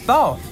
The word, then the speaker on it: bao: interesting local beers and bao. Did I say that right? – Yes, bao. Exactly.